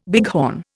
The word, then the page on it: bighorn: Indian